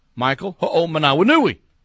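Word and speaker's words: Michael Hoomanawanui: Michael Ho'omanawanui. By the way, I asked him if my pronunciation was good, and he said, "You're spot on, dude."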